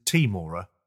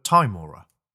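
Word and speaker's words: Tymora: Firstly, I learned that the correct pronunciation isn't Timora, but Timora.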